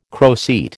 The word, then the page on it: croceate: American English